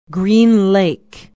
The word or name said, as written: green lake